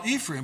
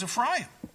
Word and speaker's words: Ephraim: And then I realized what he was talking about, what we call Ephraim, but the Hebrew pronunciation is Ephraim.